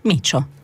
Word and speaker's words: Mitcho: Micio con Blu Erba, Micio, non scritto Micio, quello lì, è scritto M-I-T-C-H-O, Micio.